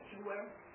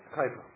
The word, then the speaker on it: Kuiper: Shewer, what do you call it called? Shewer or Cover? – Kuiper.